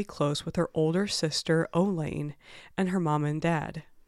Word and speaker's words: Eolane: Elaine's older sister. It is spelled E-O-L-A-N-E, and to the best of our understanding, it is pronounced Elaine.